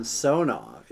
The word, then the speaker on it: Samsonov: Samsonov. You've got it with the Russians. It's always that middle syllable, you know.